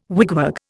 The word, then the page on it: wigwag: Indian